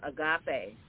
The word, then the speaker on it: agape: But, you know, to have that relationship with the body of Christ, coming that agape love to coming from Christ. – It's called agape. – I'm just seeing that. – Agape.